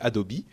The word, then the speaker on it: Adobe: Et là, vous vous dites, euh, ou plutôt à prononcer à l'anglaise Adobe ou Adobe à la française.